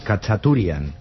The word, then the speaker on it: Khachaturian: Khachaturian.